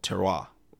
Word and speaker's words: terroir: called terroir, which claims that the land, I mean, that's spelled like terror, but with an I after the O. So it's T-E-R-R-O-I-R, terroir,